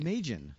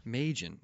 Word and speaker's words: Maedgen: Um, we just heard Halloween off of Clint What's the correct pronunciation of his last name? Maedgen. Maedgen. Okay. Just like make... Cajun, but just, spelled entirely different.